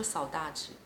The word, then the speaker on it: saudade: 他说 “saudade”，我这是用巴西的葡萄牙语发音。“saudade” 是一个没有办法。 – saudade 呃，哦，“s a u d a d e”。